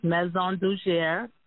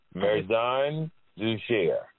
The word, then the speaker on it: Maison Dugier: It's pronounced Maison Dugier. – Maison Dugier. – Right. It's French. Now, okay, and what does that mean? – It's House of Dugier. – House of Museum? – House of Dugier. Yep. – House of Museum. – Uh-huh. And it's spelled Maison, like Nancy. U-G, like George, E-R. – Museum.